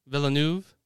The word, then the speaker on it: Villeneuve: My thing was that I love Denis, how do you pronounce his last name? Villeneuve? – Yeah.